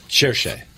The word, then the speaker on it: Cherchez: I'm going to mispronounce it, but Farche la femme? – Cherchez. – Cherchez.